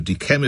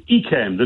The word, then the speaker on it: Yquem: It's Chateau Yquem. It's got a D, a little D in there with an apostrophe and then Yquem.